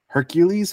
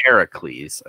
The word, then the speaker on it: Heracles: The biggest thing is like, Hercules is actually the Roman pronunciation. – Yeah. – I believe Hercules. – It's like Heracles. – Heracles. There's like, it's fully like the Greek way.